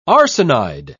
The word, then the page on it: arsenide: British English